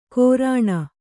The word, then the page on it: ಕೋರಾಣ: kannada